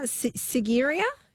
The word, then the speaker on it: Sigiriya: Now, Paul says in now, what was the pronunciation of where you are? Sigiriya. – Yes.